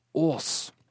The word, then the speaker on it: Aas: Jeg ble spurt How do you pronounce your name? Is it As? Fornøyd over omsider å skulle få hete noe annet enn et slengord for en bakpart, hvisket jeg tilbake No, no, you pronounce it Aas!»